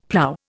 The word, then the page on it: plough: Indian